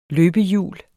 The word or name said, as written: løbehjul